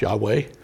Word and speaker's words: Yahweh: Now, it's rendered in Hebrew, which has no vowels in its alphabet, as Yahweh or YHWH, those letters. I'm sure you've seen them before. That's Yahweh, usually pronounced Yahweh, okay?